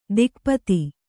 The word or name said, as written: ದಿಕ್ಪತಿ